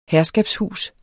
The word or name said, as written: herskabshus